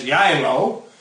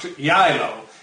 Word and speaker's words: Geilo: It would be pronounced as Jailo, because it's spelled G-E-I-L-O, but pronounced Jailo, as if the G is a Y.